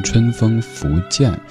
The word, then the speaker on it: 春风拂槛: 这是一个细节，刚刚说到的好妹妹乐队，他们在翻唱这歌的时候，唱的就是正确读音“ 春风拂槛”，这一点去求证的姿态，我觉得特别值得我们去肯定。